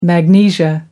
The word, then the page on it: magnesia: American English